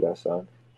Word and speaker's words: Gaston: You don't pronounce the N. He told me that. David Gaston.